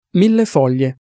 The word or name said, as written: millefoglie